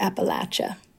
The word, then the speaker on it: Appalachia: Well, you're not wrong, but that is the northern way to pronounce it. – Oh, wait. So how do you pronounce it? Here in the south, we pronounce it Appalachia. – Appalachia. – And my godmother always used to say the way to remember is to just think of throwing an Appalachia.